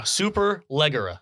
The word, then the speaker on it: Superleggera: Superleggera.